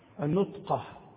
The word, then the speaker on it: النطقة: ومن بين ألبسة العرب لباس يسمى النقطة، النطقة، النطقة، النون والتاء والقاف.